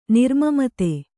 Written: ನಿರ್ಮಮತೆ

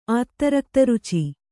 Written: ಆತ್ತರಕ್ತರುಚಿ

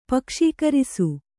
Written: ಪಕ್ಷೀಕರಿಸು

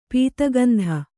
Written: ಪೂತಿಗಂಧಿ

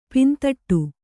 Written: ಪಿಂದೊಡೆ

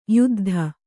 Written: ಯುದ್ಧ

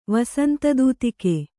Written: ವಸಂತದೂತಿಕೆ

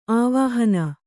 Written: ಆವಾಹನೆ

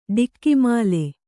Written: ಡಿಕ್ಕಿಮಾಲೆ

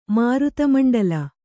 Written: ಮಾರುತಮಂಡಲ

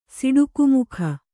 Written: ಸಿಡುಕುಮುಖ

